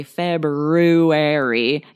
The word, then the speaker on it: February: And or that it sounds like there's no R after the B at all. So if you do that, if you stop and say February, you'll spell it right.